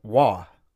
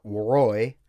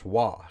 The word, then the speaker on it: Roy: Waugh. But it's spelled R-O-W. It's French. Waugh. It's not Roy. It's Waugh.